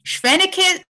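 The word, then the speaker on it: Spheniscus: It's Sphenictus. No, that can't be right. Sphenicus demersus.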